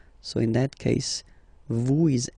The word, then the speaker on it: vous: Vous êtes. Same thing here. Notice that final S is not pronounced.